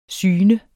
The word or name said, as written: syne